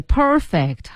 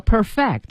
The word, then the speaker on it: perfect: 哎，对，perfect 和 perfect 是不同的发音了。